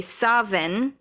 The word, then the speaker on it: Samhain: So they say Samhain, but they also say Savin. Like, there's actually no W, it's actually supposed to be a V.